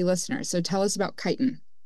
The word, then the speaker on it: chitin: Is it chitin? – Chitin. – Chitin. Okay.